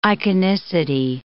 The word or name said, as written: iconicity